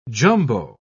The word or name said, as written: jumbo